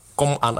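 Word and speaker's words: Công An: nevíme, jestli je to Kong An Hanoi, nebo Chong, nebo jak je to? – Kong. Kong An Hanoi. – Kon? Kon. Ko a Hanoi.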